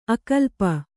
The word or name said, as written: ಆಕಲ್ಪ